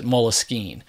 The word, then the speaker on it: Moleskine: So it was a French word, Moleskine, which is why I pronounce it Moleskine.